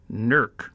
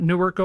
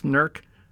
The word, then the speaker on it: Newark: So born this date in Newark, Ohio, which I always say when I mention it, you have to drop the vowels, and it becomes pronounced Nurk. Nurk, Ohio, is how you say it back in Ohio. It's not Newark, Ohio. It's Nurk, Ohio.